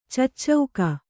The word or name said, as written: ಚೂಚುಕ